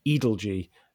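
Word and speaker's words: Edalji: And we now know it's pronounced Adelgee as a result of a letter that George wrote that's included in Shrabani's book.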